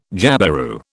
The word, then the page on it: jabiru: British English